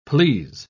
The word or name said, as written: please